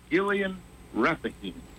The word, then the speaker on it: Gilgal Rephaim: Called the Gilgal Rephaim. That's how it's pronounced in Hebrew, the Rephaim.